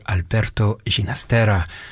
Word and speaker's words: Alberto Ginastera: from composer Alberto Ginastera, who liked to pronounce his name with that Catalan sound rather than the Argentinian one where he lived, because his father was Catalan.